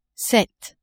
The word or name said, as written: sept